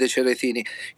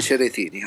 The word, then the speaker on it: Cerretini: You didn't pronounce my name correctly, not even one time, as a mistake. Guys, take some Italian lesson, come on. Chef Davide Cerretini, how hard can it be? Not Davida, not Davidi, come on.